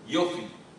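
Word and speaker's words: Yofi (יופי): Como se fala beleza em hebraico? Fala, fala. Fala bem. Yofi, yofi. A palavra yofi é beleza. Não precisa do bar, yofi.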